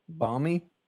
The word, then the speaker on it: balmy: Balmy? – Yeah. – The L is silent, you know.